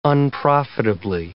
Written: unprofitably

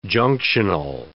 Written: junctional

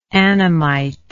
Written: anomite

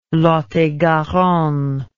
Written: lot et garonne

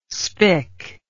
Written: spick

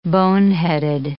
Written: boneheaded

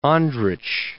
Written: Andric

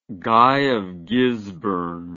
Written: guy of gisborne

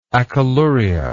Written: acholuria

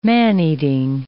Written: man-eating

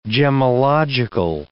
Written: gemological

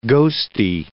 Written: ghosty

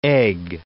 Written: egg

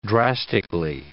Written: drastically

